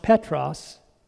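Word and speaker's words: Petros (Πέτρος): Peter, pronounced Petros in Greek.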